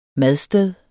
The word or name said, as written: madsted